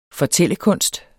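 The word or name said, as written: fortællekunst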